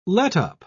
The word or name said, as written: letup